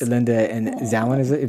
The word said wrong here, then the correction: Selwyn: Am I saying that right? – I thought it was Selwyn.